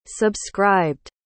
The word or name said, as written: subscribed